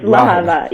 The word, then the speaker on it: Lehava: No problem. It's Lehava. Yes.